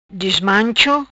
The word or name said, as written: Dismancho